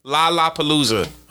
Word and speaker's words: Lollapalooza: I'm just saying, I never heard of the Lollapalooza. Do you see it? Does it look like it say Lala? – It says LOL, Lapala. It says Lola, Lapla, Lalap. Lollapalooza.